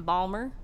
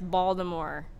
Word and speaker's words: Baltimore: Condo, Condo Land, which was done by EMP Collective in Balmer, Baltimore. – Bodymore. – Actually, I said it right. It's Baltimore.